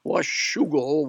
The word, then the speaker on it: Washougal: You got to be a native to pronounce the name correctly. Washougal, Washington. And we had a man by the name of Claude Hunt come for a revival, a series of meetings.